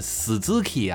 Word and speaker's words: Suzuki: 没有正经念斯的。你要是念 Suzuki 啊，人家说你这个发音不标准。